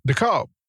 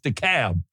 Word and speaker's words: DeKalb: This particular county happens to be, it's D-E, capital K-A-L-B, county. – DeKalb. That's how they say it around here, DeKalb. – No, they don't. It's DeKalb. – I thought it was DeKalb. – DeKalb. – Okay. – You hear the difference right there?